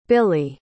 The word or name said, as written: billie